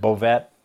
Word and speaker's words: Bouvette: So, the proper pronunciation of my last name is Bouvette. Growing up, everybody said Bouvette.